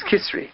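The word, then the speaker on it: Kisri (קיסרי): Kesaria. I mean, that's the Hebrew is Kesaria. Yeah. It's Kisri. Kisri is the way you say it in the Gemara.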